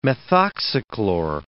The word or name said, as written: methoxychlor